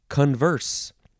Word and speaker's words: converse: Okay, this this uh, first word in this episode is the second form of converse. The second syllable is uh, emphasized. Converse. C O N V E R S E.